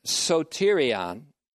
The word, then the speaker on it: soterion: With the word salvation, soterion, S-O-T-E-R-I-A-N, long O, long E, S-O-T-E-R-I-A-N, soterion, there's an exegetical move to what we call in theology, soteriology, the study of salvation, in connection with exhortation.